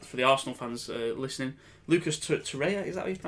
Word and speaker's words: Torreira: But anyway, with Uruguay, this Lucas Torreira. – Torreira, that's what we said.